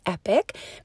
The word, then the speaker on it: Epoch: So that being said, I am going to be doing a podcast under the name of the school, which is phonetically Epoch, E-P-O-C-H, but we call it Epic because it sounds really cool.